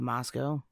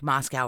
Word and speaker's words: Moscow: Because it's spelled Moscow, like Moscow, Russia, right. But they pronounce it Moscow.